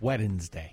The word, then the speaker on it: Wednesday: It's Wednesday. – Wednesday. I can't even do it. – Wednesday. Wednesday. – The D is silent.